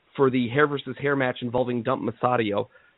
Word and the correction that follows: Matsumoto: I just butchered her name, but I do think that's a relevant and important thing. – Dump Matsumoto, yeah.